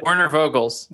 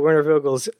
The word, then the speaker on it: Werner Vogels: But Werner Vogels. – There you go, Werner Vogels.